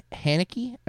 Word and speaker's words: Haneke: Michael Haneke, I believe is how you say his name.